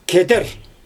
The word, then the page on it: keder: Xyzyl